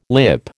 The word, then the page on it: lip: American English